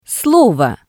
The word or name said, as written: slovo